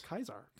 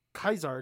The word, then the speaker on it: Caesar: Kaisar. – Kaisar? – Because that if you went to Rome to ancient Rome, it would be Kaisar, because that's how you would pronounce it in actual Latin.